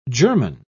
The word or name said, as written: german